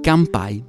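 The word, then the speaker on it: campai (乾杯): Si dice campai, metti il campari, levi la R, fai campai.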